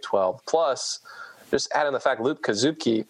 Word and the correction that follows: Kasupke: Kasupke. – Looks good to me. – Actually, I went to the phonetic pronunciation of the media guide. It's Kasupke.